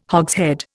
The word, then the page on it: hogshead: Indian